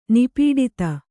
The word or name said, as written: ನಿಪೀಡಿತ